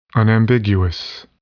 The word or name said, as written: unambiguous